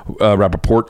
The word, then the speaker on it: Rapaport: Whitney Cummings and Michael Rapaport. Rapaport, apparently you're supposed to say it like that.